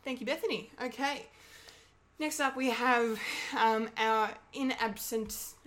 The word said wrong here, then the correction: in absentia: Was it in absentia? Is that how you say it? That's the phrase? – Something like that, yeah.